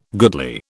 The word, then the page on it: goodly: British English